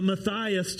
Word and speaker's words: Matthias: And they discover that the Lord would choose Matthias, which I mispronounced in the first verse, uh, Matthias to be uh, this new apostle.